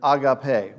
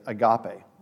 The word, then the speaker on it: agape: that word love is the Greek word agape, or we sometimes say agape. So agape is probably the better pronunciation of it.